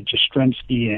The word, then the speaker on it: Jastrzemski: even though it's a weird name because it rhymes with a pretty famous guy, Jastrzemski, and the other guy's McNally.